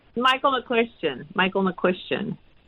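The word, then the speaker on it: McCuistion: Close. It's Michael, Michael McQuestion.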